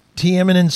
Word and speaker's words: Tiananmen: Liam, I was in China and I got a really bad stomach ache, and I was in Tiananmen Square.